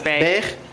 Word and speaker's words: Beghe: B-E-G-H-E? How do you pronounce that? – Beige.